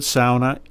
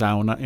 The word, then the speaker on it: sauna: So the word sauna is a uniquely mm-hmm. Finnish word, and it's pronounced sauna in Finland. And being from a Finnish family, I will always pronounce mm-hmm. it sauna.